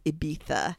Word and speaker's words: Ibiza: He was like, oh, and by the way, it's pronounced Ibiza. I was like, oh, you better tell him about the Castilian lisp.